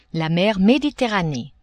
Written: mer Mediterranee

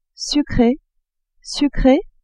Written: sucre, sucree

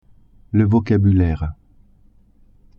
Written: vocabulaire

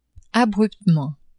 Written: abruptement

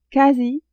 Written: quasi

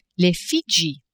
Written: Fidji

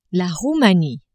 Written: Roumanie

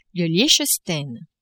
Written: Liechtenstein

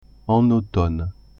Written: en automne

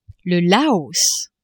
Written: Laos

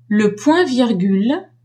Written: le point-virgule

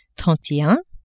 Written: trente et un

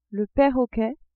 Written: perroquet

